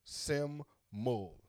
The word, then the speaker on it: Simul: Look, Fennel currently performs under the solo project Simul, Welsh for simple, pronounced Sim-mul.